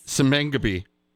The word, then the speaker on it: Simbang Gabi: Simbang Gabi. – Yep. – Say it like a champ. – Simbang Gabi. – Simbang Gabi. – Ah, you got it.